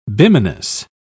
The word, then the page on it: bimanous: British English